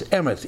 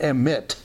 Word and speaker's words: emeth: So his name reflects God because in the Old Testament, truth is emeth, E-M-E-T-H. E-M-E-T-H is the Hebrew word pronounced emet. And it has a T-H sound on the end, not emit, but emeth, E-M-E-T-H, emeth. And it means truth and is usually translated by the Greek aletheia.